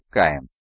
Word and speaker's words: Хайон: На низком готике Уральской области Терры Искандар произносят как Сихандур, а Хайон как Каин.